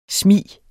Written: smig

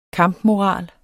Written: KAMPMORAL

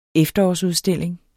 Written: efterårsudstilling

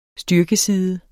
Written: styrkeside

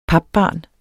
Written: papbarn

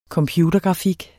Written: computergrafik

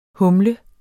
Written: humle

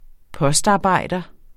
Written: postarbejder

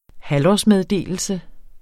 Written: halvårsmeddelelse

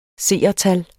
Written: seertal

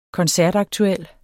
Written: koncertaktuel

